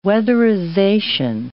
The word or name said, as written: weatherization